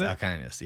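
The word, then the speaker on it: Alcaniz: Tell me, how do you pronounce this? Alcanis Street? What is it? – Yeah, Alcanis. Now you got me messing up on it. – Alcanis? – Yes, Alcanis, yes.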